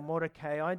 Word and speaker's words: Mordecai: We're introduced also to an older Jewish man named Mordecai, or Mordecai, depends on how you want to pronounce it, and a young Jewish orphan named Hadassah.